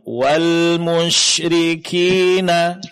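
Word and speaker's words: Wal musyrikina (وَالْمُشْرِكِينَ): Tinggal untuk menyempurnakan, sedikit ditingkatkan dalam pengucapan huruf seperti huruf syin, yaitu sifat tafasinya. Wal musyrikina. Coba, Bu. – Wal musyrikina. – Ya, jadi bunyi ash pada huruf sinnya lebih jelaskan.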